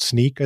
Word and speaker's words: Snyk: And so they were using a tool called Sneak, I think it's pronounced. SNYK. – Yeah. – Sneak.